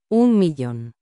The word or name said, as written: 1000000